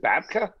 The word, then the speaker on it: babka: Is it babka? – Babka.